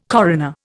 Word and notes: coroner: Indian

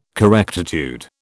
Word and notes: correctitude: British English

